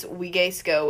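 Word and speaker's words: vigesco: Well, in Wigesco, it's spelled like it starts with a V, so you would never know.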